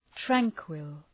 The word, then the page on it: tranquil: ‘træŋkwəl